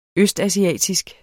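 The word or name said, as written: ØSTASIATISK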